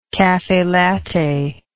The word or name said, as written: caffelatte